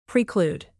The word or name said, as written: preclude